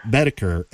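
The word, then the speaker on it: Boetticher: Bedeker.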